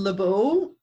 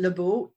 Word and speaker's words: LeBeau: Either way, so Lynn is spelled L-Y-N and last name LeBeau, L-E-B as in Bob, E-A-U.